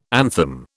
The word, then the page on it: anthem: British English